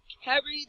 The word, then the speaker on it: Karrie: It is Carrie the Puck, K-A-R-R-I-E.